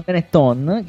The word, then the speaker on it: Benetton: Tornando alla Benetton, che è la prima volta che lo pronuncio bene nella mia vita, le 22.00, grande presenza.